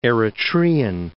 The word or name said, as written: Eritrean